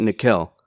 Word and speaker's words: Nickel: Like, that's how you pronounce it, Nickel. – Nickel. – It's like Raquel, but ni instead of ruh.